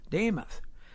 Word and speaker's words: Damath: Like DeMuth? Joe Namath. – Like Joe Namath, only Damath. – Yes. – Damath.